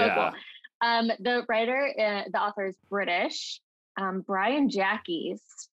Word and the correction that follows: Jacques: I think that's pronounced Jacques.